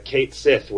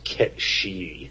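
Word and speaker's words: Cait Sith: Anyway, but the thing's name is C-A-I-T space S-I-T-H. And there is a certain kind of guy that if you say, "Oh yeah, that Kate Sith was my favorite Esper," they'll say, "It's pronounced Ket-She."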